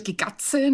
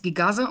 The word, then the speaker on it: Gigatze: es wird Gigatze, nicht wie du es gesagt hast, Gigase ausgesprochen.